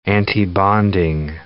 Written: antibonding

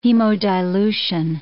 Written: hemodilution

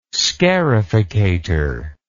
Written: scarificator

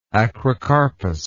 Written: acrocarpous

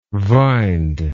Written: vined

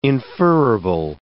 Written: inferrible

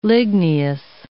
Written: ligneous

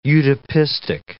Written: utopistic

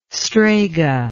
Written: strega

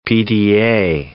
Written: PDA

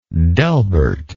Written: Delbert

